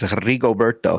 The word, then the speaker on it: Rigoberto: You got to roll the R. I think it's Rigoberto.